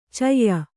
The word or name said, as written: ಛಾಯ